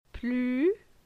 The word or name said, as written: Plu